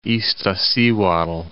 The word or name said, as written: Iztaccihuatl